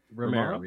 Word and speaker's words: Romero: How do you say the guy's name? Romero? Oh, Romero, yeah.